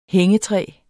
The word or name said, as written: hængetræ